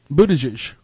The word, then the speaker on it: Buttigieg: And the only way I know how to pronounce Mayor Pete's last name is because I heard him say his name. – Yeah, well, I'm glad you can, because... – Buttigieg.